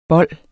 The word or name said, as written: bol